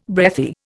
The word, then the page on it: breathy: Indian